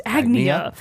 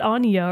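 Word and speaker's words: Agnea: I can now confirm her name is pronounced Agnia. – Okay. – Yeah, it's not Anya or any of the other ways that Bravely Default chose to pronounce the name Agnes Agnes, which is what threw me off because I thought they would be really similar, but they're not.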